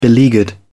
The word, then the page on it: beleaguered: British English